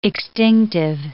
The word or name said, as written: extinctive